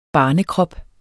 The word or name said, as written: barnekrop